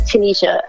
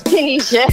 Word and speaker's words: Tynisha: I'm like, yo, I love you so much, but it's Tynisha. I know my mom a little bit ghetto and she put the Y, but it's Tynisha.